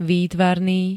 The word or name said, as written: výtvarný